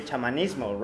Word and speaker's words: chamanismo: In Spanish, we say chamanes, and chamanes. So it would be chamanismo, right? Because it's a Spanish word.